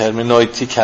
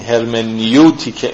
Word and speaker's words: هرمنوتیک: همچنین که دوستان توجه دارن بنده به تعمد هرمنویتیک هم تلفظ میکنم، میخوام به یونانی نزدیکش کنم. این هرمنیوتیک انگلیسی و هرمنوتیک فرانسه،